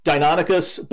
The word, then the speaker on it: Deinonychus: Ostrom preferred the pronunciation Deinonychus, but honestly, you know, the dinosaurs are dead. – I get them wrong. I always get it wrong anyway. So a lot of people use that pronunciation too, so that's fine.